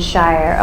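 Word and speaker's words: Scheier: If you want to look that up, Father Stephen Shire. Oh, I should have spelled it on there for you. It's S-C-H-E-I-E-R. Stephen, spelled with a V.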